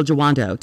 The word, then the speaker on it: Jawando: So, ladies and gentlemen, boys and girls, those who don't identify as either, please welcome Will Jawando to Ratchet and Respectable.